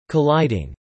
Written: colliding

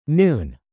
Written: noon

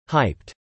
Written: hyped

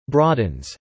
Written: broadens

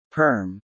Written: Perm

